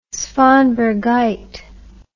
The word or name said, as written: svanbergite